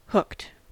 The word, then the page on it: hooked: American English